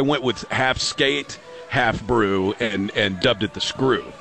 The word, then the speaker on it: Broe: My personal favorite that I came up with was the Brew, B-R-O-E, which is half brew, half shoe. I thought that was pretty good, but I think that people would mispronounce it. They'd call it the Bro or the Broey.